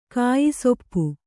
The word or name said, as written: ಕಾಯಿಸೊಪ್ಪು